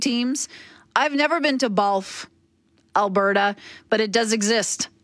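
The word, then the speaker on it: Bawlf: Isn't there a town called Bolf? I think it might be spelled B-A-W-L-F. I could be mistaken, but I think there's a town in Alberta called Bolf, Alberta. And that rhymes with golf.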